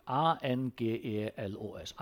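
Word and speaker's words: angelos (ἄγγελος): Så, A, det 2G, men du uttaler dette som et N, det 2 sammen, det sin grisk grammatikk. A, N, G, E, L, O, S, angelos.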